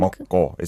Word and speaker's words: 먹고: Is that almost like a hard 기억 sound? Almost like a double 먹고. – Yes. Yeah, double 기억 sound. – 먹고.